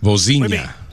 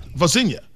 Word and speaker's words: Vozinha: I don't know how to pronounce the goalie's name necessarily, but I bought his jersey going into extra stoppage time. – Vozinha. – Vozinha.